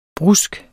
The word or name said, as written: brusk